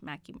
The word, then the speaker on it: Mackay: So the Mackay Mansion, which it is pronounced Mackay Mansion. It looks like it'd be like Mackay. – Mackay. – But it is Mackay Mansion.